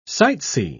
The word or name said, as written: sightsee